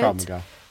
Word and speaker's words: Krav Maga: Is that how you say it? – Krav Maga.